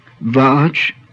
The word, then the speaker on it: Vach: initial V is pronounced as in English. Vach, speech, also the mystic personification of speech.